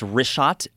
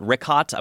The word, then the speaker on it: Richat: Yeah, the wrist shot, R-I-C-H-A-T. He's got it right here. Yeah. I just want to say it's wrist shot. I used to call it Rickot. I was mispronouncing it.